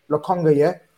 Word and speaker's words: Lokonga: Lokonga.